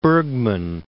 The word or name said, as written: Bergman